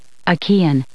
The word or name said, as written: achaean